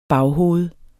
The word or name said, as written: baghoved